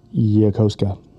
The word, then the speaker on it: Yokosuka: It's pronounced Yokosuka. – Well, that's wrong.